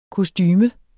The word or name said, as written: kostume